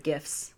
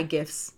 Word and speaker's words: GIF: We also got several really good GIFs. Say GIFs, not GIFs. – Yeah, because we're Team GIF over here at Just Keep Rolling. Because, you know, graphic is, in fact, a hard G.